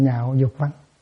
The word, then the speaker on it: nhạo: Chứ không có nói nguyện lạc dục văn. Nó là chữ lạc, nhưng mà đọc là chữ nhạo. Sau này sẽ có một chữ lạc khác, mà cũng phải sửa lại thành chữ nhạo.